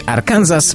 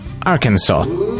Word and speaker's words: Arkansas: Choć w naszym kraju najczęściej mówi się Arkansas, poprawna wymowa to Arkansas.